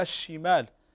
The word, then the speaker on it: الشمال: وكذلك ليست الشمال، لا الشمال، هذه اللام لا تنطق، هذه اللام لا تنطق.